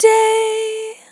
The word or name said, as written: Long Day